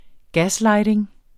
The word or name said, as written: gaslighting